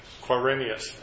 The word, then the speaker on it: Quirinius: Quirinius, again, governor of Syria.